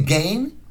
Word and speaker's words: Gein: I say Gein, because to me, E-I is pronounced A, like neighbor and way.